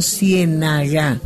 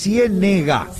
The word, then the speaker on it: Ciénega: Y una pregunta, una inquietud, si es Ciénega o Ciénaga. – No, es Ciénega, sí, con E. – Con E. – Con acento en la E, Ciénega.